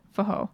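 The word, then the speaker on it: forró: This type of music is spelled F-O-R-R-O, but I think with Portuguese pronunciation, pronounced Fajo.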